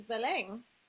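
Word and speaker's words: Belén: So my name in Spanish is Belen, and people have the hardest time pronouncing that.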